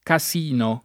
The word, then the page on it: casino: ka S& no